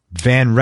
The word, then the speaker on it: Van: Ron, his last name is Van Herpen, V-A-N. Am I saying that right? – Van. Van. – Okay. Yeah. Ron Van Herpen. It's V-A-N-H-E-R-P-E-N.